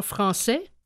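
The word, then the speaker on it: Françaix: Dans la marche triomphale du compositeur Jean Français, qui s'écrit C-A-I-X,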